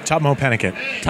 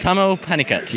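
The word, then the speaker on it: Tahmoh Penikett: How, your name is pronounced? – Tomo Pinnicutt. – Tomo Pinnicutt. – Yes.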